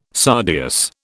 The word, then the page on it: sardius: British English